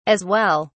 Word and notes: as well: British English

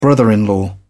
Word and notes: brother in law: British English

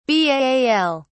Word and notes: baal: British English

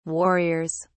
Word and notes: warriors: British English

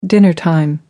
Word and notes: dinnertime: British English

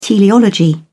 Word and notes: teleology: British English

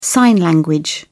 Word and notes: sign language: British English

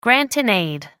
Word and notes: grant-in-aid: American English

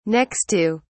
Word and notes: next to: British English

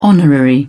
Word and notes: honorary: British English

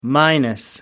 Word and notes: minus: American English

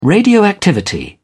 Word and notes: radioactivity: British English

